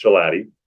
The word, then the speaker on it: Shalati: My last name is actually anglicized Arabic. It's very hard to pronounce and spell. It's Shalati.